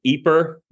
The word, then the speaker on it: Ypres: He then ends up back in his hometown of Ypres, all these weird kind of French names in this area. This is Y-P-R-E-S, and I believe it's pronounced Ypres, which was his hometown, and slowly tries to start preaching the gospel again, without associating publicly with Luther.